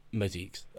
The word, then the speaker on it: Mosaics: I can never say it. Mosaics. Say it, you, – Mosaics, Mosaics. – mosaics. – That's it. I can't. It doesn't sound like it's spelled like that.